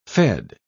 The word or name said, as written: fed